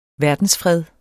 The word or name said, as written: verdensfred